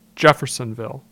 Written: Jefferson

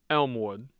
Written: Elmwood

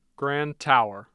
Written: Grand Tower

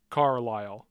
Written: carlyle

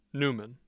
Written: newman

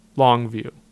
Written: Long View

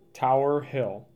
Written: Tower Hill

